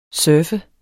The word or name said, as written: surfer